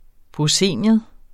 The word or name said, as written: proscenium